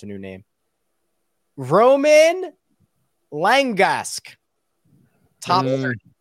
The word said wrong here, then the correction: Romain: No, you said Roman. – Romain, like the Lex. Like the salad. – You got to roll the R.